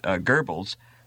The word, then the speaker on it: Goebbels: And one of the things that I discovered in my own research was that Goebbels, who did the... Is that the way it's pronounced? – Yeah, I call him by another name.